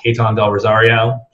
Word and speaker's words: Caton Del Rosario: Uh, you can find me, it's just my name, at Caton Del Rosario, C A T O N D E L R O S A R I O.